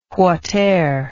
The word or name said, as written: whateer